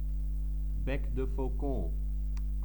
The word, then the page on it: bec de faucon: French